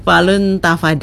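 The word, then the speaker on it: Falun Dafa: Menulisnya Dafa, tapi bacanya Tafa, begitu, ya. Pakai huruf pinjin ini, pinjinnya Mandarin, ya. Falun Tafa Day, sehat jiwa dan raga dengan latihan meditasi.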